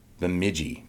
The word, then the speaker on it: Bemidji: Bemidji. – Bemidji State University.